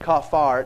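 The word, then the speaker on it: kafar (כפר): It's pronounced kafar. It's spelt in the English transliteration, K-A-P-A-R.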